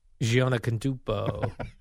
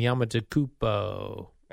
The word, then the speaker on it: Antetokounmpo: Giannis Antetokounmpo. Giannis and Antetokounmpo.